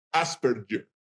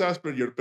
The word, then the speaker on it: Asperger: Asperger. La pronunciación es Asperger, pero la verdad no me molesta si es Asperger o Asperger.